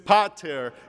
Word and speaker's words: Pater: And so I started, "Pater Hemon." And he goes, "It's Pater." The accent's on the first syllable.